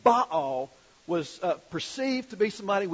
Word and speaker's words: Baal: the religion of the Baals. B-A-A-L-S. Now, in Hebrew, that term is pronounced Baal. In North Carolina, we pronounced it Baal. You know, in North Carolina, you can make almost any word two syllables.